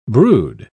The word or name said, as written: brood